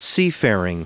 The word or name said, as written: seafaring